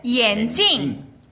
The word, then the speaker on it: 眼镜: 요거는 이제 발음을 주의하실 게, 안경은 眼镜. – 眼镜. – 眼镜. – 眼镜. – 근데 요거 주의하실 점 있죠? 경성으로 眼镜 하면 뭐죠? 눈이 돼요, 눈.